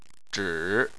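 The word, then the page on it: zhi: tone 3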